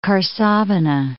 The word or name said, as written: Karsavina